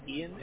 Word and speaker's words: Ian: My name is Ian.